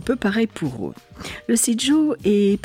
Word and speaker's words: sijo: Alors moi je dis Sijo, mais normalement c'est Sijo. Ça s'écrit S-I-J-O.